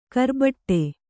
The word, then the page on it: ಕರ್ಬೆಟ್ಟು: karbeṭṭu